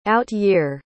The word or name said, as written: out-year